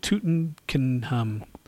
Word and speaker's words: Tutankhamun: Tutankhamun.